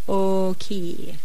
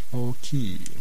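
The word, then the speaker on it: oki (大きい): And the word for big in Japanese is oki. – Oki.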